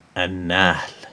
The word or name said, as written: An-Nahl